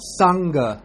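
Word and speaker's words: saṅgha: The N with a dot on top would be pronounced like in the English word song, NG, or ring, NG. So like we say sangha or sankara. You see, it's there's no other way you could pronounce it. It always has to come out like sing or song or ring.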